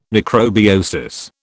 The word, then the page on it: necrobiosis: British English